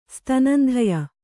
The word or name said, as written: ಸ್ತನಂಧಯ